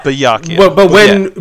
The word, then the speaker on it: Byakuya: So, Yakuya, and then just put a but at the beginning. But Byakuya.